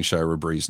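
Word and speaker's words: Shira Brie: That is, and first off, let me compliment Mark for pronouncing Shira Bree's name correctly.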